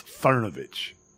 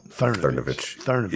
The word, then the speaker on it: Cernovich: You should have the respect for the man to pronounce his name properly. It's Cernovich. – Oh, Cernovich. He has very much earned that meme.